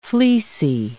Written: fleecy

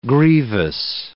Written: grievous

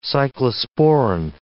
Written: cyclosporine

